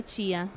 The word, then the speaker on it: chia: In realtà si pronuncia Chia, allora Chia.